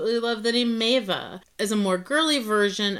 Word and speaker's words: Maeva: Well, the Polynesian version of the name is pronounced Maeva, and I believe that's the name that is becoming popular in kind of the French cultures, Maeva.